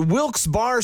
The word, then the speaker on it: Wilkes-Barre: And you're listening to Wilkes Barre Scranton's 97.9X. Now, I had heard that it was supposed to be pronounced Wilkes-Barre. Wilkes-Barre Scranton's 97.9X. The only way to pronounce Wilkes-Barre.